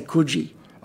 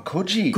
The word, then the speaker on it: Coogee: You've got to say Coogee. – Oh, Coogee. – Coogee. – Thank you for correcting me. – Yeah. – Coogee. I've been saying it wrong all this time.